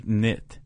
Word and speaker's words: cniht: C-N-I-H-T, knit.